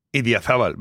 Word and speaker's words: Idiazabal: How do you pronounce that? – Idiazabal. Very good.